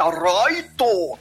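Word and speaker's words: raito (ライト): L eles pronunciam e, R e o R eles pronunciam L. E letras mudas eles não deixam muda, eles põem um O, um U. – Por isso, isso que em vez é. de light é raito. É rai, fica raito.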